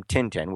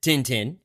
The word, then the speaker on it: Tintin: But here in our trailers and the audio for the film version here, they do call him Tintin, which is the way one might pronounce his name if you just glance at the word.